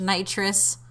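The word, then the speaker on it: Knightress: And so for those listening at home, to try and distinguish Nitrous from Nitrous. It's spelled K-N-I-G-H-T-R-E-S.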